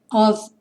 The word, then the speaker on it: of: Dust in your hair of gold. Of tem a pronúncia do F como se fosse um V.